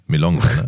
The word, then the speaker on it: Melonga: Oder Melange? Ja. Nein, Melonga. Ne? Du kannst es auch Milonga aussprechen, weil das soll ja das Wortspiel sein.